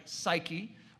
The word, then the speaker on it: psyche: I know it looks like psyche or psyches, but I'm giving you a Greek word here. It's pronounced psyche, yes, and we get our word psyche from this word.